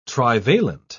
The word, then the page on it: trivalent: British English